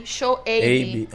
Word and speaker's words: Shoaib: O nome, pô, Shoaib, porque o, o I tem som de ei, Shoaib.